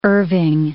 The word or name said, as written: Irving